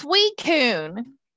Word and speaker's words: Suicune: It's Suicune.